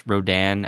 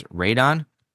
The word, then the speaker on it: Rodan: For instance, everyone pronounced Rodan as Radon, which is how it's spelled in the original Japanese. But we all know, at least in English, that's not how you say the creature's name.